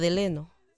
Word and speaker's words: Delano: Bueno, se escribe Delano. – Delano y se pronuncia Deleno.